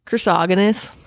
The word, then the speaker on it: Chrysogonus: Chrysogonus.